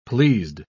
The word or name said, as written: pleased